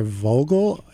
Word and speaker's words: Vogel: Dr. Linda Vogel, a psychologist.